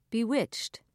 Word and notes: bewitched: British English

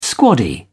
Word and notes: squaddie: British English

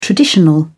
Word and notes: traditional: British English

